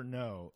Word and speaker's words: Noé: Gaspar Noé's movies have made me so uncomfortable. I don't know why I keep watching them. – I want to, Gaspar Noé. Yeah. – N-O-E. – N-O-E.